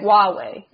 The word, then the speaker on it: Huawei: Am I pronouncing that correctly? – Huawei, I think. – Huawei, yes, because of the Huawei issues.